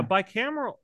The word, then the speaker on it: bicameral: bicameral mentality. Father, are you familiar with this term? I know I'm butchering how to say it, but like bicameral.